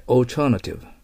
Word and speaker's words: alternative: alternative じゃなくて、 alternative です。はい、発音記号を見てみましょう。 alter となってますよ。はい、 alternative。だからこれ発音間違ってオルタネイティブなんて覚えてたら聞き取れるわけありませんから、意味は。